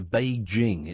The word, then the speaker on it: Beijing: I went to Beijing. It's a hard J.